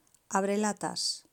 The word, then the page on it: abrelatas: Spain